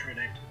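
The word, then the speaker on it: Trünicht: It's pronounced like Trinict. – Trinict, okay. Alright, so I gotta remember these characters.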